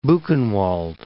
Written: Buchenwald

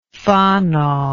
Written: Fano

